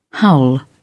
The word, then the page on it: howl: British English